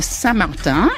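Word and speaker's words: Saint-Martin: The island is Saint Martin, pronounced Saint Martin in French.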